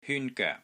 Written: HYUN1 GOEK3

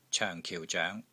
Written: COENG4 KIU4 ZOENG2

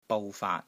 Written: BOU6 FAAT3

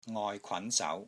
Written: NGOI6 KWAN2 SAU2

